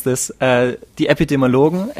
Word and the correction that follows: epidemiologist: Ep uh, epidemi yeah. – Yes, those guys. Uh, yeah, those okay. the most important guys right now. What they say. Epidemiologist. Epidemiologist. Okay.